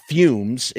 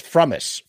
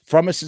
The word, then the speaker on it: Frumess: It's not fumes. It's Frumess. Frumess is how you pronounce the name.